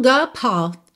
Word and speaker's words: apart: E atenção que esse meu apart já tá com a pronúncia britânica. O A, o segundo A, alongado, certo? O R não enrola. E o T já tá sumindo aqui, que é uma opção que eu tenho, né? Apart. Não preciso fazer apart.